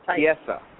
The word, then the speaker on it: Chiesa: Did I pronounce that correctly? – Chiesa. Mike Chiesa.